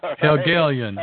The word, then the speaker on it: Hegelian: Hegelian, yes. – I can tell you're not German. George Wilhelm Friedrich Hegel. – Yeah, the Hegelian, yes. – Right, there you go. It's two syllables, not one.